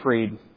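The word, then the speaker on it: Fried: Just this past week, the king of crypto, Sam Bankman, is it Freed? Anybody heard the name pronounced? Freed? Okay, so German-like roots where it's pronounces the second of the two in a diphthong. So Sam Bankman Freed.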